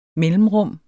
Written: mellemrum

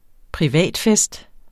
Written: privatfest

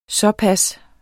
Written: såpas

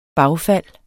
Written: bagfald

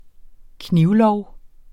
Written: knivlov

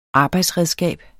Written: arbejdsredskab

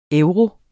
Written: EUROS

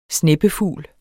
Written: sneppefugl

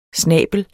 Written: snabel

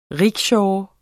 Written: RICKSHAW

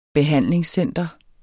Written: behandlingscenter

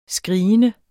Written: skrigende

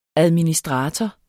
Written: administrator

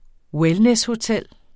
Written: wellnesshotel

